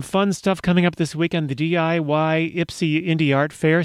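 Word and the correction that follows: DIYPSI: See, I've already mispronounced it. It's actually Dipsy. It's spelled DIY with the I repeating itself once, but not really repeating as PSI.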